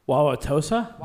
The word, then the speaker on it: Wauwatosa: W-A-U-W-A-T-O-S-A. – Wauwatosa? – Wauwatosa, thank you.